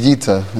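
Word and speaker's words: Yita (איטא): It's pronounced Yita. It's spelled Aleph Yud Tes Aleph, but he should have a refuah shalema and come back gesundheit.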